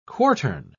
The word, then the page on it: quartern: British English